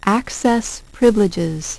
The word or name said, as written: access privileges